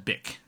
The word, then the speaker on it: Bich: Pronounced Bic. B-I-C-H space H-A. Bitch Ha.